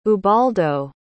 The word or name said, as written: ubaldo